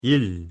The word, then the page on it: 일: Korean